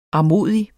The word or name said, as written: armodig